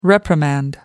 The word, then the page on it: reprimand: American English